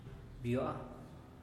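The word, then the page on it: biua: Teotitlán del Valle Zapotec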